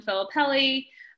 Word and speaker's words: Filippelli: I mean, the best place to find me is on Instagram, at Caitlin Filippelli. Very simple. My last name is spelled like Mississippi, double L, double P, double L, literally same exact letters.